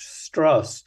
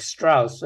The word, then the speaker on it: Strauss: He makes a point of having his name pronounced Strauss so that it doesn't sound like Strauss and people would think he was Jewish.